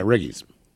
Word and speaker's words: Riggies: Google this. Chicken Wriggles. I'm assuming they spell it with an R. – Igglies. – Igglies. – Oh, Riggies. Riggies? – Yeah, Riggies.